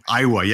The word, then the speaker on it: Aiwa: In fact, in Back to the Future, it was in Aiwa. Is that how you pronounce it? – I- Rival. Uh, Aiwa, yes.